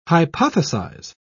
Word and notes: hypothesise: British English

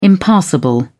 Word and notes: impassable: British English

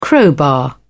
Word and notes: crowbar: British English

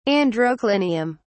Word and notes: androclinium: British English

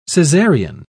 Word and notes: cesarian: British English

